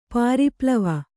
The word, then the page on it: ಪರಿಪ್ಲವ: Kannada